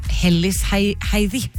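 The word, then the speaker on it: Hellisheiði: Jeg akkurat nå på Hellisheidi på vei ut fra Reykjavik. Det vel nærmest, tenker jeg. Tror du jeg uttalte det riktig? – Ja, jeg følte at det hørtes veldig bra ut, ja.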